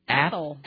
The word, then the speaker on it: Athol: Athol. – Athol. – A-T-H-O-L.